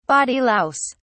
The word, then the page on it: body louse: British English